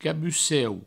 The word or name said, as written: cabuceu